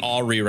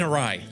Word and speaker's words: awry: Yeah, things are really going awry right now. I mean, awry. Shoot. – You put the emphasis on the wrong syllable.